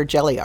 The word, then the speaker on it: Gelli: We'd like to take a moment to thank our sponsor, Jelly Arts. It's spelled G-E-L-L-I.